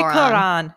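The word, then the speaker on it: Klorane: And if you're looking for like a more affordable range, definitely check out Chlorane. Is that how you say it? Chlorane? Chloran. – I say probably Chloran. Yeah. Okay. Chloran. Mm.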